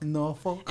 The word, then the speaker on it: Norfolk: I flew in from Norfolk, Virginia. – Did she say how you 757, Norfolk. – Norfolk. – And I think I'm saying it right because, you know, everyone says it different. I'm right.